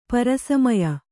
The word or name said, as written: ಪರಸಮಯ